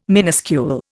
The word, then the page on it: minuscule: Indian